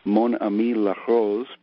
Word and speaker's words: Mon Ami La Rose: Forgive once again my pronunciation, but uh, I have Mon Ami La Rose by Natacha Atlas. – I want to say, first of all, your pronunciation was just fine. I think you did that R very well.